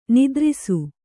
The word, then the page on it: ನಿತ್ತರಿಸು: Kannada; nittarisu